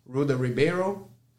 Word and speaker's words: Ruda Ribeiro: My Instagram is R U D as a dog A H R-I-B is a boy, E-I-R-O, Ruda Ribeiro.